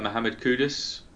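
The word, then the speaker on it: Mohammed Kudus: Yeah, I'd like to give a shout out for Mohamed Kudus, Yes.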